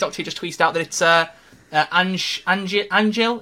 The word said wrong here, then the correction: Anjli: Is it how you say her name? Anjli. Anjli.